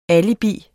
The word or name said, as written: alibis